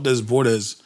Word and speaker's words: Desbordes: I think it's spelled Desbordes, but it's pronounced Desbord. – Dubois. – Yeah. – Like the S's are silent.